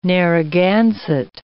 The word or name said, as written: Narraganset